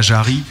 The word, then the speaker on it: Jarry: Et puis, euh, ce qu'on va écouter tout de suite, euh, c'est votre premier live acoustique, messieurs de la Jarry. Ce qu'il faut prononcer comme ça, puisque c'est déjà l'heure, avec juste une petite minute de retard, ce qui est plutôt pas mal.